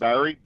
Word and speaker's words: Barry: That's Barry, B-A-R-R-Y. There are no E's in Barry. – I'm sure Gary's also spelled G-A-R-Y, so pretty darn close.